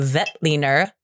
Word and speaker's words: Veltliner: And she said that my pronunciation of Veltliner was perfect.